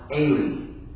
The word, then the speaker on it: Eli: In Aramaic, Elijah's name is pronounced Eli.